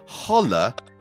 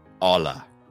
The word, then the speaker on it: hola: When you say holla, the H is silent, so just say hola."